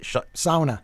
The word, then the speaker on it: sauna: How do you pronounce it? – Sauna. – Sauna. Sauna. Because you've been to Finland, and so you got the right.